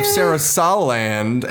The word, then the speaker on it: Sarasaland: She's the princess of Sarasaland. I think it's how you say it. – Yeah, that was right.